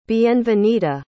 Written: bienvenida

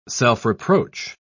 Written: self-reproach

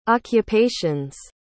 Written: occupation's

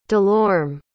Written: delorme

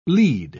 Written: lied

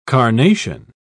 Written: carnation

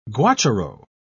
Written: guacharo

